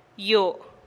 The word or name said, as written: yoo